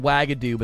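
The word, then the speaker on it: Uagadou: It's not spelled Wagadoo, but that's how you pronounce it phonetically.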